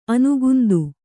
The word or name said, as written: ಅನುಕಾಂತ